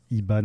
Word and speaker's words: Hiba: Donc, voilà, dans ces artistes un peu moins connus, vous pouvez suivre Iba, donc ça s'écrit H-I-B-A.